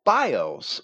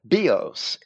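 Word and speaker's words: bios (βίος): This is appropriate as Liddell and Scott explain that zoe describes a life, but bios, or actually that should be pronounced bios in Greek, as the course of life, the livelihood, or even the manner or means of living.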